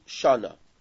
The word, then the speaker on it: shana: In Hebrew, the word year means shana. Now, how do you say Hebrew, the year? Shana. How do you say month? Chodesh. What's the difference between chodesh and shana?